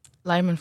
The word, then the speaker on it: Lyman: Namelijk eh, de, de moord tijdens de, tijdens de Small Council meeting op Lehman. Zeg ik dat goed? Lyman? – Lyman volgens mij, ja.